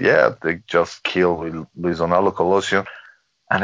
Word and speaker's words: Luis Donaldo Colosio: We were talking about a couple of things from the 90s, Chupacabras, and then you mentioned the assassination of Luis Donaldo Colosio.